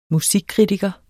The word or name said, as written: musikkritiker